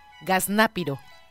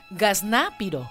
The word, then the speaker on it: gaznápiro: Pero hay una palabra que significa lo mismo y es gaznápiro. – Gaznápiro. – Gaznate, no, gaznápiro. – ¿Gazná? – Gaznápiro. – Gaznápiro. – Ajá, okay. Con Z. Gaznápiro y acento en la A.